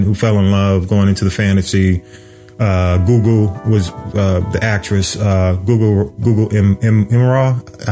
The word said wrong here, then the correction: Gugu Mbatha-Raw: But Gugu Mbatha-Raw is the actress in that.